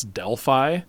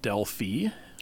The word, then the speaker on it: Delphi: And it's a place that you and I might pronounce Delphi. The Greeks pronounce it Delphi.